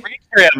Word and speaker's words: Eritrea: Eritrea? E-R-I-T-R-E-A? – Eritrea, man.